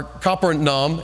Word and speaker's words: Capernaum: But Jairus, I mean, this is in Capernaum, or Capernaum, as I learned how to say it in Israel.